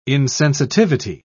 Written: insensitivity

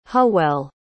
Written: hywel

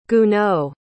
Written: gounod

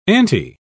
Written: ante

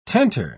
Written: tenter

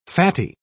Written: fatty

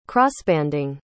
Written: crossbanding

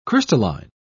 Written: crystalline

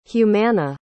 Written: humana

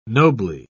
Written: nobly

